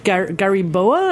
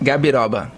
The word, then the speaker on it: Gabiroba: Also the Farmhouse Ale, which has yet another tropical fruit in it, the Gariboa? I'm going to pronounce it. – Gabiroba.